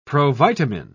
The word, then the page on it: provitamin: British English